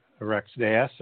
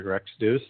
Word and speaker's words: Rex Deus: Yes, and so you used the term that I use in the book, Rex Deus or Rex Deus, depending on how you want to pronounce that, and that means kings of God.